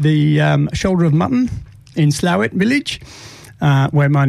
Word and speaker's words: Slaithwaite: Well, it's pronounced Slawit, but if I spelt it out for you, S-L-A-I-T-H-W-A-I-T-E, you would say Slaithwaite. – Slaithwaite. – But the local pronunciation is Slawit.